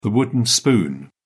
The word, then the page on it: wooden spoon: British English